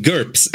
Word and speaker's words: GURPS: It's called GURPS, actually. – GURPS, yeah. Did I say something different? – You said GURPS.